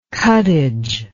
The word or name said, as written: cuttage